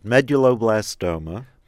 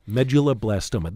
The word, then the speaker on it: Medulloblastoma: Medulloblastoma. – Medulloblastoma.